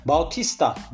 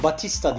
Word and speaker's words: Batista: Bautista diye okunuyor fakat Batista diye şu anda hem yazılıyor hem de telaffuz ediliyor değil mi Canberk? – Evet.